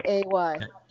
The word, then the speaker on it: eh: It's spelled E-H, guys, not A-Y. – E-H, yeah. – E-H. A. – A? A. You got to say it like it's a question.